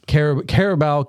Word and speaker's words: Carabao: How do you pronounce that? Cup? – Caribou is how I pronounce it. – Carabao Cup, a repeat of last year's final against the Liverpool Reds.